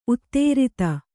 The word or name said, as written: ಉತ್ತರಾಧಿ